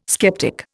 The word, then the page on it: skeptic: Indian